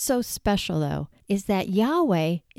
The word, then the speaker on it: Yahweh: Without going into too much detail, and you can study this more on your own, but when vowels were introduced, the most accepted way to pronounce the word was Yahweh. It has also been pronounced as Yehovah in Hebrew, and it later was converted to Jehovah.